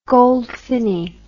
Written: goldfinny

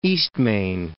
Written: Eastmain

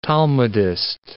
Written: Talmudist

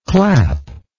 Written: clapster

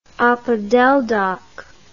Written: opodeldoc

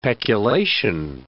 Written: peculation

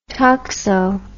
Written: toxo

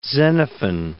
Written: Xenophon

